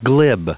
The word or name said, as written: glib